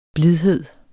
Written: blidhed

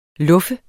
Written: luffe

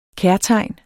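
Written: KÆRTEGNETS

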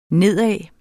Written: nedad

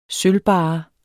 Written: sølvbarre

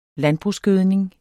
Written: landbrugsgødning

